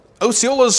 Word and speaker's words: Osceola: He was a Seminole Indian chief, and it was Osceola, not Osceola.